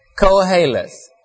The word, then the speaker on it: Koheleth: but the best I'll give to you and from myself is Koheleth. It's sometimes spelled with a capital K-O-H-E-L-E-T-H, and sometimes it starts with a Q, depends on where you read the word.